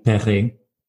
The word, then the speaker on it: Perret: But it means my surname is French, is pronounced the French way, Perret, which made me sort of stand out slightly, but it gave me no advantage with the French language at school.